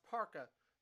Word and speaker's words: parka: Now, it's not pronounced perka, it's pronounced parka, people, parka. Everyone says per when it's actually par instead, so funny thing there for you all to know, so.